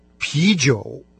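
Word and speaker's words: пи-джоу (啤酒): Пиво по-китайски «пи-джоу». Первая часть этого слова – она произносится со вторым тоном, это калька с немецкого «бир».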